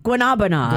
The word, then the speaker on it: Guanabanas: Guanabanas. – Guanabanas is the correct pronunciation, but everybody says it wrong.